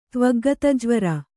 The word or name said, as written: ತ್ವಗ್ಗತಜ್ವರ